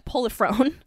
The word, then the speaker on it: Polifrone: There's an interview, actually, interview transcript with Prolifone or I just did I just butcher his name? Yeah, Polifrone.